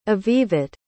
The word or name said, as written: avivit